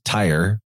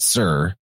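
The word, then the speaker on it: Tyre: I know that it looks like it says tire, but you actually pronounce that Sir. And knowing that is very helpful because you'll hear everybody in the world pronouncing it tire.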